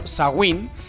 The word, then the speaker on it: Samhain: Así como Beltane marca el inicio de la mitad soleada y horny, Samhain, pronunciado Sawin, es la festividad celta que marca el inicio de la amistad oscura y fría.